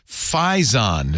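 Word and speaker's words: Phison: Fizon.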